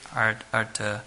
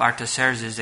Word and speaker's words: Artaxerxes: This is the decree by King Artaxerxes, I believe that's how you say it. Artaxerxes. He was a king of the Medo-Persian Empire.